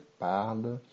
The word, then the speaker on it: parlent: Eh, algo que hay que fijarse es en la eh, tercera persona del plural, que es il, el, parle. Aunque hay una ENT, se pronuncia igual como la tercera persona del singular.